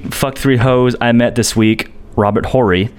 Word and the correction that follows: Horry: Silent H. – Sorry. Apologies. I don't know old hoes. Oh, no, no. So I just hit a three-peat, fuck three hoes I met this week, Robert Horry.